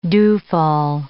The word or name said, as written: dewfall